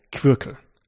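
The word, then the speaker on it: Qwirkle: Und das Brettspiel heißt Quirkel. Habe ich es richtig ausgesprochen? – Genau, ja.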